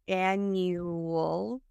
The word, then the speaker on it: annual: So it sounds like there's a W in there. Annual conference.